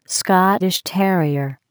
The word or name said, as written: Scottish terrier